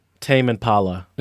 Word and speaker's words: Tame Impala: Tame Impala.